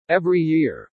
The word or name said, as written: every year